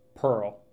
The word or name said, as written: Pearl